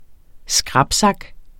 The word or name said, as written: skrapsak